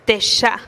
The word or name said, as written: teexa